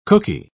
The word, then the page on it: cooky: British English